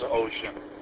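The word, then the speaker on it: Wouxun: It's W-O-U-X-U-N or something like that, but it's pronounced ocean.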